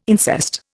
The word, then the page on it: incest: Indian